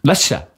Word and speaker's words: Leicester: Leicester sagt man, glaube ich. – Einfach. Leicester. – Ja, so wie eine Leicester-Schwester. – Ah, alles klar. – Ich glaube, da wird eine Silbe, die bei Leicester quasi zu lesen wäre, wird einfach verschluckt.